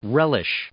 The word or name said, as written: relish